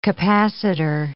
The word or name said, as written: capacitor